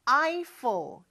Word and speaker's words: Eiffel: 자, 영어로는 조금 발음이 다르죠. 에펠이 아니고 Eiffel, 이렇게 발음을 해요.